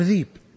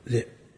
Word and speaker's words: ذئب: عندنا طالب من بيشة قبل 15 سنة أو أقل عندنا في، نقول له ابن أبي ذئب، عجز ينطق، يقول له ذيب، يقول له شلون ذيب، ذئب، أخذ لسانه راح الذيب جاء الذيب، والله عجز عنه ينطق ذئب،